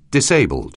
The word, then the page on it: disabled: anglais